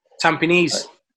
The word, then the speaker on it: Tampines: Tampines. – Tampines Rovers. – That's how it's pronounced, Tampines Rovers, in Singapore.